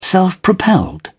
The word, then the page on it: self-propelled: British English